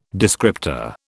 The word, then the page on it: descriptor: British English